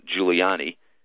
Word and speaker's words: Giuliani: Just search for my name, Charles Giuliani. Last name is spelled G-I-U-L-I-A-N-I.